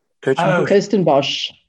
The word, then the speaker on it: Kirstenbosch: I mean, the last place we played was, see if I can pronounce this correctly, Kirstenbosch? – Kirstenbosch. – Kirstenbosch.